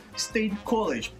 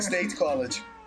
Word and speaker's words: State College: Próximo e-mail é do Marcos Nunes, 29 anos, doutorando em estatística, State College. Nossa, viu? Que pronúncia horrível. Amiga, State né? College. – É, State College.